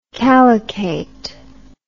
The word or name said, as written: calycate